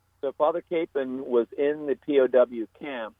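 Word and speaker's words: Kapaun: So pick up where you left off with, now, which way do you prefer to pronounce his name? – I call him Father Capon.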